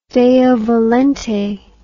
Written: deo volente

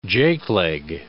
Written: jake leg

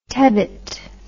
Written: tebbitt

